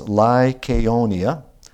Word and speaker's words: Lycaonia: So, and it's pronounced Lycaonia.